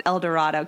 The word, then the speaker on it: El Dorado: I'm pretty sure it's El Dorado because I would have pronounced it El Dorado, Kansas, but it's apparently pronounced El Dorado.